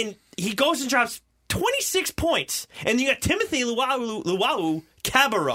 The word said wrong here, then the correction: Luwawu: Timothy Luwawu.